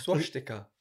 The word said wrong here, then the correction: swastika: Swash sticker. That's right, isn't it? – Swastika. – Swastika. Oh, okay.